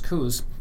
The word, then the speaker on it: Coos: And rather cleverly, the first thing they're going to do is they've linked with Coos County in Oregon, which is spelled the same as Coos County, but it's pronounced Coos. Doesn't have the little umlauts, you know, the little dots over the O.